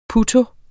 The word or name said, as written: putto